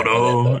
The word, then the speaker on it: Todo: So, yeah, very clever for her to try and convince, or to convince Toso, or Togo, what is his name? If I'm pronouncing it wrong, but yeah. – Todo, I think it's Todo, yeah. Todo.